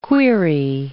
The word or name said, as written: query